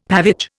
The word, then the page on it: pavage: Indian